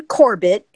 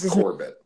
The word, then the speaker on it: Corbett: I think Austin Coburt, I think that's how you pronounce his name. I think he's going to be a great addition to the line. – It's Austin Corbett. – Corbett.